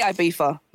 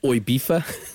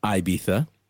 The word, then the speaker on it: Ibiza: Number one is the drunk Brit, Oibifa. – Oibifa. – The pretentious music snob is Ibiza or Ibiza. The nightlife professional is Ibiza.